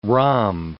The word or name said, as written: rhomb